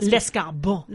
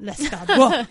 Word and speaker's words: Lescarbault: So anyway, this guy, Edmund Modeste, I'm going to totally butcher his name, Lescarbault? I do not. – Lescarbault. – Lescarbault, yes. I'll go with that.